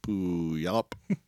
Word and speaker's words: Puyallup: Puyallup, which is actually pronounced Puyallup.